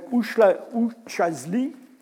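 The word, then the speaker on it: Üçağızlı: On va trouver ça en Turquie, un site qui s'appelle Uçhisli. Ça ne s'écrit pas vraiment comme ça, mais c'est comme ça que ça se prononce.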